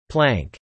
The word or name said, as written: plank